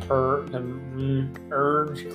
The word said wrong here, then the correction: Burj: So Dubai is correct, and I think it's pronounced Burj. Yeah, U R J.